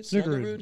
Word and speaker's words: Snuggerud: Is it Snuggerud? Is that how you say it? – Snuggerud.